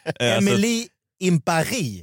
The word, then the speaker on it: Emily in Paris: Ja, Netflix gick ut med en rättelse att folk säger fel. – Ja, jag vet. Lite som jag tidigare uttalade Erin Perrin. – Exakt så. – Ja, Emily in Paris. – Ja, så ska det vara, ja.